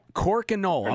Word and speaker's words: Crokinole: I didn't quite I'm going to, yeah, Crokinole.